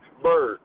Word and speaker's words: Byrd: Bird. B-Y-R-D. – B-Y-R-D? – Yes.